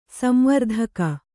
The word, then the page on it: ಸಂವರ್ತಕ: samvartaka